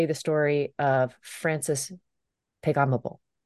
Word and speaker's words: Pegahmagabow: That's good, because I actually missed a syllable. Pegahmagabow. That's what it's supposed to be.